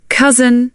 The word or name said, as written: cousin